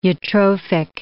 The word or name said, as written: eutrophic